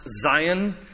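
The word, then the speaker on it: Zayin: If you'll take a glance at verse 49, the Hebrew letter there is pronounced Zion. Zion, verse 49, says, "Remember your word to your servant, for you have given me hope.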